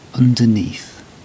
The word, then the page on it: underneath: British English